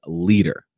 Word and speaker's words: LEADR: And so several weeks ago at an all-staff meeting, we were introduced to this organization called LEADER. Now, it's spelled L-E-A-D-R. And they did this on purpose, believe it or not. They actually spelled it this way.